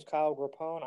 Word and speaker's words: Grappone: So it's pronounced Grappone. – Grappone. Okay, Kyle, go ahead and introduce yourself. – Sure. So as you said, my name is Kyle Grappone.